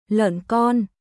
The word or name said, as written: Lợn con